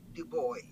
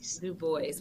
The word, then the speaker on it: Du Bois: So he has the French pronunciation, but he said Du Bois. – Du Bois.